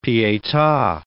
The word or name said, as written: pieta